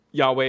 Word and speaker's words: Yahweh: Apparently, in Hebrew, there is no true J sound. That's a little bit more of an English or Germanic sound. If we're trying to stay as close as possible to the Hebrew pronunciation, Yahweh is our best guess.